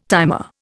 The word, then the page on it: timer: Indian